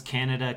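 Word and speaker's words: Can Ada: And the street name is Can-Ada, like it's Can, C-A-N, space, A-D-A, and they pronounce it Can-Ada, so.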